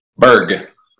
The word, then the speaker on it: Bourg: How do you pronounce your last name? Berg. Say that again. – Like an iceberg.